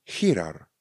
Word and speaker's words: girar: Nebo chirar. Chirar. Píšeme girar. r r na konci. Girar. Píšeme, vyslujeme chirar. Jednoduše. Ano. Ch, nebo respektive G a I vyslujeme jako ch.